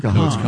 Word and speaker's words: Kahan: Is it Kahan or Kahn? – No, it's pronounced Kahn. It's Noah spelled K A.